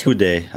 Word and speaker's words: Houdet: I think it's Houdet. Andre Houdet. Uh, you say it like you want.